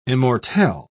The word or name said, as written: immortelle